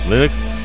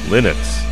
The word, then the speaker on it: Linux: I pronounce Linux, Linux.